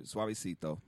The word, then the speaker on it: Suavecito: So with all of this going on, you got this new record, Suavecito.